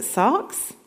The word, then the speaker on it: sarx: I'm going to try and pronounce it, sarx. Getting a nod from David, so I've done okay.